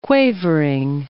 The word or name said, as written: quavering